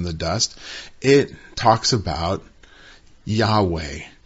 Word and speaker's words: Yahweh: It's the name of God, which is always expressed by those four letters, which we transliterate in English into YHVH, and we pronounce as Yahweh.